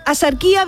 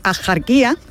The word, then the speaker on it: Axarquía: Y aunque hoy la RAE deja claro, la RAE dice que lo culto y correcto es pronunciarlo con J, es decir, asarquía, la tradición local sigue pronunciando y no solo escribiendo asarquía o asarquía, ¿no? Con una H aspirada, con una, sí, una H aspirada.